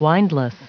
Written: windless

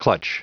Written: clutch

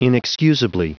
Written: inexcusably

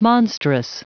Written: monstrous

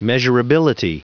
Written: measurability

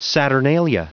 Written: saturnalia

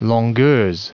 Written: longueurs

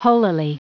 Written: holily

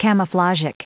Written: camouflagic

